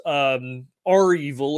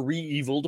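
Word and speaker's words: REvil: In fact, one of the most prolific and successful ransomware groups, R-Evil or Re-Evil, depending on how you want to pronounce it, they were targeted because they were really so successful at ransomware.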